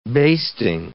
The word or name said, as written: basting